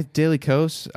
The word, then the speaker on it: Daily Kos: Is that how you pronounce that? – Daily Kos. That's always how I pronounce it.